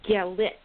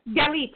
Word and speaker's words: Galit: And so without further ado, I'm just totally going to introduce Galit. Did I pronounce it right? – Galit, yes. – Galit, there.